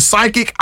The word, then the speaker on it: psychic: Right, because when we see psychic, it's spelled P-S-Y, right? – I couldn't tell you. – Yes, yeah, this, yep. – So the P is silent.